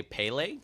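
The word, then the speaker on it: Pelé: I want to say Pele.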